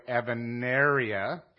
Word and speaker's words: Evanelia: And like a lot of Tahitian words, it's spelled one way and pronounced another way. And it's really Evanaria because they don't have an L in their language.